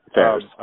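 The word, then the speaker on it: Phares: I'm guessing your counterparts like Steve Huey and Ned Raggett and Heather Ferris, or whatever you say her name. – Ferris.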